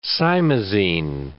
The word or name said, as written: simazine